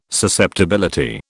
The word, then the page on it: susceptibility: British English